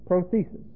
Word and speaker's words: prothesis: Prothesis, see? P-R-O-T-H-E-S-I-S.